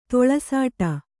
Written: ತೊಳಸಾಟ

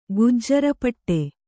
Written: ಗುಜ್ಜರಪಟ್ಟೆ

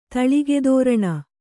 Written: ತಳಿಗೆತೋರಣ

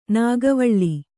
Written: ನಾಗವಲ್ಲಿ

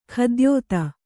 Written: ಖದ್ಯೋತ

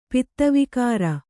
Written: ಪಿತ್ತವಿಕಾರ

